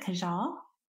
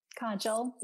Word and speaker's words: kajal: I think you pronounce it Kajal. – Kajal, yeah.